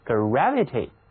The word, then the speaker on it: gravity: 大家要注意 gravity 这个词中间的字母 A，它读作比较扁的那个梅花音 gravity，